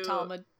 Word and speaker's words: Talmud: Talmud. – Talmud? – Yeah.